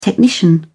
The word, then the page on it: technician: British English